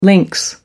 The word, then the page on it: links: American English